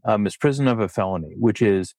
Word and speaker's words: misprision: I did have to go to Google Translate to make sure I pronounced it correctly. It's misprision. Misprision.